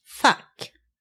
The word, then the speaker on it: fack: Then we have the word fuck, F-A-C-K, fuck. That means either a little compartment somewhere. There can be a fuck in a chest of drawers, a little compartment.